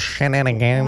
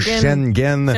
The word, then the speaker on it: Schengen: Schengen, ça prononce Schengen. – Moi, c'est Schengen. – Ou Schengen.